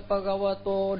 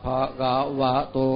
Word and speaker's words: Bhagavato: Đừng có đọc là Nam Mô Tassa Bhagavato, đừng có đọc ngoài môi đó. Mà đọc cái âm ở bên trong. Nam Mô Tassa Bhagavato.